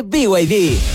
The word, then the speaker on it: BYD: Se escribe BYD, pero se dice BYD.